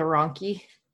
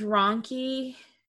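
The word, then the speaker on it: rhonchi: Not as bad as I feel when people have like the ronchi, because that's like a lower pitch gurgly sound. And it just sounds wet. It sounds. – Yeah. But I feel like with ronchi, which is spelled really weird, right? It's R-H-O-N-C-H-I.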